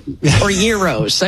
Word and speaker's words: gyros: If you're going to go Greek, it is a euro.